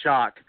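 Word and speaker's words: Shough: It's S-H-O-U-G-H, but I think it's pronounced Shock.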